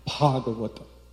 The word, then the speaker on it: Bhagavata: So they all say, Bhagavata. Bhagavata. Bhagavata.